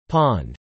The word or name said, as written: pond